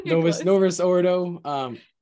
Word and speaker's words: Novus Ordo: Nova Ordis or Norvo Ordis. How do you say Novus Ordo? – Novus Ordo.